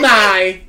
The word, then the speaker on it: Nye: Uh, Nike? How do you pronounce it? Bill Nye. Bill, Bill Nye. Nye.